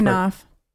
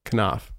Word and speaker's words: Knopf: Is that how you say it? Knopf? – It's Knopf. – Knopf.